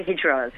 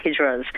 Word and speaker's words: hijras: Now, we wanted to ask you a bit about hijras. And have I said that right? – You have. It's really hijras. Yep. It's hijras.